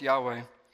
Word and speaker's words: Yahweh: Since the time of Moses, I am, or the Hebrew YHWH, which is pronounced Yahweh, or Jehovah, has been so very sacred.